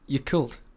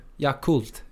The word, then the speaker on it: Yakult: Why do you say it like that? – I don't know. I said just Yakult. – Okay. – How do you say it? – Yakult. – Yakult. – Yeah. – Yakult. It's basically cult.